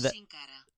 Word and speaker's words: Sin Cara: And Sin Cara.